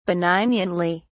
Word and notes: benignantly: British English

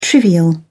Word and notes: trivial: British English

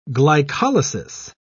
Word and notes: glycolysis: British English

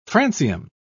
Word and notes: francium: British English

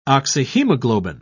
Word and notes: oxyhemoglobin: British English

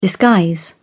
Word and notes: disguise: British English

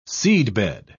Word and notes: seedbed: British English

